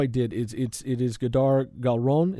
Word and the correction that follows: Hadar: Her first name, I want to make sure that we're both saying it right, is Hadar. – Hadar. – It's H-A-D-A-R, yes.